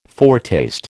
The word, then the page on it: foretaste: American English